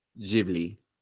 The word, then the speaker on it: Ghibli: Where Hayao Miyazaki himself says the word. It is pronounced Ghibli.